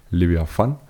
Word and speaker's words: Leviathan: Så havde bragt vi et lydklip fra filmen Leviathan, som vi jo har diskuteret, hvordan vi skal udtale, men vi har altså valgt at gøre det på den russiske måde, så vi gentager lige Leviathan.